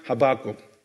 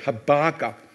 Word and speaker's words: Habakkuk: In my country, where I came from, we pronounce Habakkuk, okay? And here, I believe more like Habakkuk.